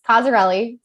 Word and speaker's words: Cozzarelli: It's Cozzarelli. – Okay. So that's what I thought. – It's actually, it's pretty phonetically spelled, but the Z's I think are scary. So totally understand.